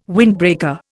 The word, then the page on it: windbreaker: Indian